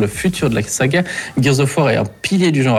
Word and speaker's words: Gears of War: On termine avec Gears of War. C'est bien ça, je le prononce juste. – Oui, c'est ça, c'est juste.